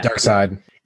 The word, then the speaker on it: Darkseid: And I'm saying that right? It's Darkseid. – Yeah, Darkseid.